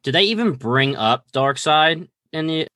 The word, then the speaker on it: Darkseid: And I'm saying that right? It's Darkseid. – Yeah, Darkseid.